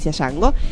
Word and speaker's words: Django: Y la D no se menciona porque no se pronuncia Django, se llama Django sin cadenas, uh-huh.